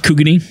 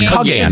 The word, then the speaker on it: Kugane: A thread popped up over a good old r/FFXIV entitled, A Plea to the User Base to Stop Abusing Kugane. – It's pronounced Kugani. – Kugain. I'm sorry. – I thought Kugane. – It's Kugane. – Kugane. I'm sorry. Kugane. – There you go.